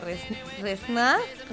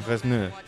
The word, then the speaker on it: Resnais: Cineastes com Truffaut, Godard o Resnais... En francès, la S no es pronuncia. – No, Resnais. Sí, sí que es pronuncia. – Sí? Resnais van dur a terme... – La primera, la segona no.